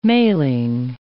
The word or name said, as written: mailing